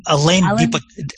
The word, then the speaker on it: Alain de Botton: Is it Alain de Botton? – Alain de Botton.